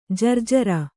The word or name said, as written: ಜರ್ಜರಿ